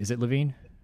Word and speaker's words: Levine: Is it Levine? – Yeah, it's Levine.